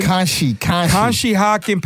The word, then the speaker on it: Conshohocken: It's a great cigar house out in, how do you say it? Conshohocken? – Conshohocken, Pennsylvania. Conshohocken.